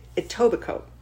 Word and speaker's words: Etobicoke: Etobicoke. Of this, Etobicoke.